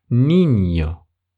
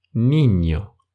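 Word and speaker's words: niño: Либо другой пример. Ребенок. «Ниньо». «Ниньо». В этом слове первая буква – это «эне», и вторая буква это «энье» с нарисованной волной над этой буквой. «Ниньо».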